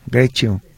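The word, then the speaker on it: Greccio: Grecio. Eh, eh, póngale, es G-R-E-C-C-I-O. c c de casa, Grecio. Cuando es doble C se pronuncia como la Che de charro, Grecio.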